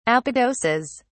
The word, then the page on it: apodoses: British English